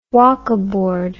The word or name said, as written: wokkaboard